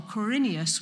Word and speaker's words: Quirinius: This was while Quirinius was governor of Syria.